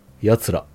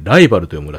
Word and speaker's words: 奴ら: 奴ら。あ、失礼。奴らと書いてライバルと読むらしいですね。